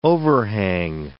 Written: overhang

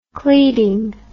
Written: cleading

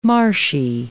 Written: marshy

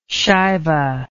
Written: shaiva

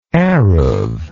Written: erub

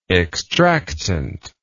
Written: extractant

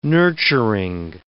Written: nurturing

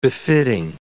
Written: befitting

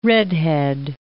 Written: redhead

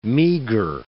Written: meager

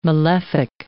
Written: malefic

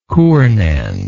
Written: Cournand

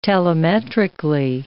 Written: telemetrically

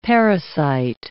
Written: parasite

